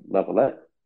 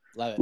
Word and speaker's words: Leavitt: But also, I wanted to get your thoughts on Paddy Pimblett versus Jordan Level. I can never pronounce his last name. Leavitt. I think his name is Leavitt. – Leavitt. There you go.